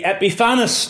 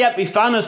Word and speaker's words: Epiphanes: Now, it's one of those words, it's hard necessarily to pronounce, and the correct pronunciation is up for debate. It could be Epiphanes, depends how you emphasize your syllables. I say Epiphanes because he was also known as Epimanes.